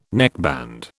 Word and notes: neckband: British English

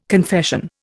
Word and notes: confession: Indian

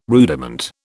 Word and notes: rudiment: British English

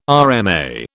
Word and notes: RMA: British English

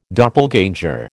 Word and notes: doppelganger: American English

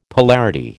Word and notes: polarity: American English

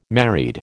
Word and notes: married: American English

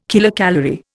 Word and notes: kilocalorie: Indian

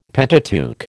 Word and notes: Pentateuch: American English

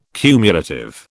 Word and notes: cumulative: British English

